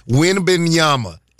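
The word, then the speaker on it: Wembanyama: I cannot tell the people what happened, but I can pronounce his name. Wimbenyama is his name.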